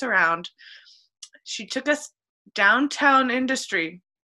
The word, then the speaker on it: Industry: It's called Industry, Illinois. And what I've learned is you have to put the emphasis on the in, right? Is that right? Industry. – I typically say industry.